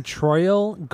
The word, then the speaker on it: Troyal: Oh, dude, he was born Troyal Garth Brooks.